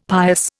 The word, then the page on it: pious: Indian